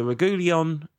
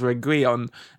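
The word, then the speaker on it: Reguilón: I mean, so the deal that seemed to be somewhat likely was the Reguilon, sorry about pronunciation. The only thing I know is that the emphasis should be on the I in Reguilon, because that's what accents mean in Spanish, because I've got a 520-day Duolingo streak going.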